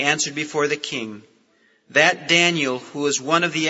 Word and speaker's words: Daniel: The name Daniel has 3 syllables. You pronounce it Daniel. And each one of those syllables in Hebrew has a meaning. Dan means judge. The little E sound in the middle is the Hebrew word for my. And El, the Hebrew word for God.